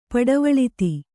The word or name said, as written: ಪಡವಳಿತಿ